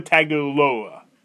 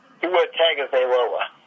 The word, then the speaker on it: Tagovailoa: Tua Tagovailoa. Tua. – Tua Tagovailoa.